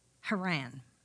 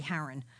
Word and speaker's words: Haran: Terah begat Abram, Nahor, and Haran. Actually, I think that's pronounced Haran. I'm sorry, Haran.